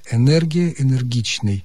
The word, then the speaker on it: энергия: Что касается мягкости или твердости «н», а во втором слоге прилагательного «энергичный» или существительного «энергия», орфоэпические словари рекомендуют твердый «н». Энергия, энергичный.